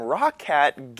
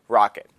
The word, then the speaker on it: ROCCAT: I'm with TWiT and Before You Buy, and today I am reviewing some Rock Hat gaming accessories. I called them Rock Hat because it is spelled R-O-C-C-A-T. The way they actually pronounce it is Rocket.